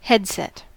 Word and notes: headset: American English

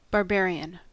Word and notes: barbarian: American English

